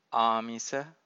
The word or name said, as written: amisa